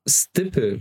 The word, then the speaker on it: Stippl: Und zwar wollte ich äh, dort über das Unternehmen Stippel sprechen. Ich glaube, so spricht man das aus. Es wird geschrieben S-T-I-P-P-L.